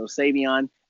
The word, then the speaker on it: Savion: Okay, so Savion, and my name is spelled S-A-V, as in Victor, like I always tell people, I-O-N.